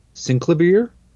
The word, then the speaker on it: Synclavier: Synclavier? – Synclavier. That's how Henry pronounces it.